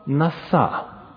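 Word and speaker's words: наса (נָשָׂא): Это древнееврейское слово «наса», ударение на последний слог.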